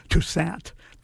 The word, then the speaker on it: Toussaint: In case you're wondering, when I said Toussaint, yes, he's on some of his live recordings, he says his name, and yes, it is Toussaint, French pronunciation with a soft A.